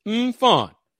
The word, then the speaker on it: Mfon: But after this edition of Folks Talking Sports, give me some time and I will produce my interview with his sister, Mfon, and it's Mfon. That's how it's pronounced. That's how she pronounced it. Mfon, M-F-O-N.